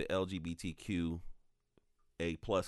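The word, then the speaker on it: LGBTQA+: my commentary wasn't about the LGBTQ A plus community.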